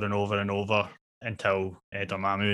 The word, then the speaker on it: Dormammu: is it Morgamo? I can't see it. – Do you mean Dormammu? – Oh, Dormammu, that's it. Sorry, I knew I was saying it wrong.